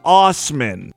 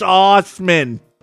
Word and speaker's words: Asman: He pronounces it as Osman. That sounds like bullshit. That sounds like something a child would come up with because he's being made fun of in school about his last name. It's not Asman, that's not how you pronounce it. It's Osman.